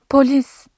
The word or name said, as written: polis